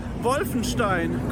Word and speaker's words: Wolfenstein: And I just want to tell you how to pronounce Wolfenstein correctly. It is a very German-sounding word for sure.